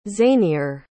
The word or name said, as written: zanier